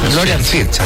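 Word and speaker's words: Florian Wirtz: Nos vamos al ataque, Leroy Sané, Jamel Musiala, Florian Wirt, no, es Florian Fierz. Florian Fierz, así se pronuncia.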